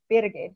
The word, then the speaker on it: Birgit: But the original pronunciation is Birgit. It's a bit Birgit. Like Burnham.